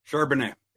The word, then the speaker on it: Charbonnet: Charbonnet. – Is it Charbonnet? A little silent T there.